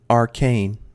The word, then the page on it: arcane: American English